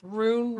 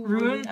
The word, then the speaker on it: Rhun: How do you say that? – Rune. – Rune. Okay. Rune and Harad.